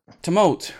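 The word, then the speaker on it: Tumult Royale: What I'm going to go with, because I don't think we've ever spoken about this game, Totem Royale. No, that's not how you say it. – Tumult Royale.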